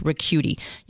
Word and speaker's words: Ricciuti: And it's kind of hard to spell. R-E-C-C-H-U-I-T-I. It's pronounced Ricciuti.